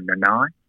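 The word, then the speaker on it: Nanai: So, look, I'll probably have to go towards Jeremiah Nenai, or Nenai, the way you pronounce it.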